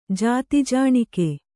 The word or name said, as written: ಜಾತಿಜಾಣಿಕೆ